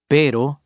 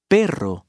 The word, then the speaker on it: perro: A mispronunciation will often change the meaning of a word, for example, pero, but, versus perro, dog.